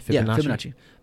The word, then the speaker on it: Fibonacci: Am I saying it right? – Fibonacci.